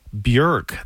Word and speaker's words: Björk: And before that, Iceland's Björk. That is how you pronounce her name. You've been saying it. I've been saying it wrong this whole time. Björk. But in Iceland, they call her Björk.